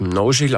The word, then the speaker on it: Mnozil: Also Mnozil ist eigentlich ein tschechischer Eigenname und wird Mnozil ausgesprochen im Original und bedeutet so viel wie Fruchtbarkeit.